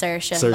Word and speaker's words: Saoirse: When I was a child and nobody else was called Saoirse, for the record, it's Saoirse, like an Saoirse. – Yeah. – And people in Ireland actually pronounce it Saoirse, so take your pick.